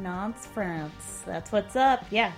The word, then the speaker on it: Nantes: It's pronounced Nantes. – Is it really Mm-hmm. Nantes, France? – Nantes, France.